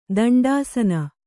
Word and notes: ದಂಡಾಸನ: Kannada

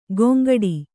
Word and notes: ಗೊಂಗಡಿ: goŋgaḍi

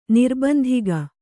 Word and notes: ನಿರ್ಬಂಧಿಗ: Kannada

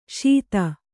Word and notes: ಶೀತ: śita

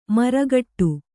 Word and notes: ಮರಗೋಡು: kannada

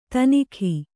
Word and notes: ತನಿಖಿ: tanikhi

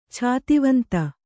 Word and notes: ಛಾತಿವಂತ: Kannada

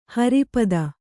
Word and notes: ಹರಿಪದ: Kannada

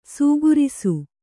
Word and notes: ಸೀಗುರಿಸು: kannada

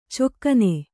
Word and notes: ಚೊಕ್ಕನೆ: cokkane

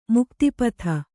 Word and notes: ಮುಕ್ತಿಪದ: mukti pada

